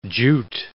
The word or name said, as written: jute